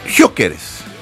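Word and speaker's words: Gyökeres: Jokeres.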